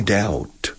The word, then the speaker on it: doubt: I doubt. Hay una B mudísima aquí que la gente a veces pronuncia, pero no, it's muda. Doubt. Como deuda, debt, doubt.